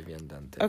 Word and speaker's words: Viandante: I wanted to highlight that Mario has a podcast that's launching very shortly, and it's called The Viandante. – Yes, The Viandante. – Okay, and that's spelled T-H-E space. – No, V-I-A. V-I-A-N-D-A-N-T-E.